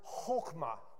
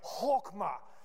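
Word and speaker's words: chokmah (חכמה): The word is chokmah. And the only way you can really say it right is if it really sounds like you're going to hawk something up. So it's like chokmah.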